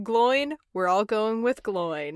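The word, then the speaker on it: Glóin: it's not pronounced Gloin as in groin. Are you kidding me? No, it's Gloin because there's an accent over the I.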